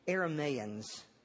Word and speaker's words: Arameans: Arameans is correct. But for some reason I want to say, never mind.